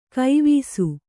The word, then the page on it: ಗವಿಸು: gavisu